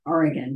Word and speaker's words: Oregon: And I want some, oh my God, I stopped in this boutique in Baker City, Oregon, right? Oregon, excuse me. They call it Oregon, even though it's spelled Oregon, but whatever.